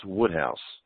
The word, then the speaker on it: Wodehouse: His name is spelled W-O-D-E-H-O-U-S-E, but it's pronounced Woodhouse.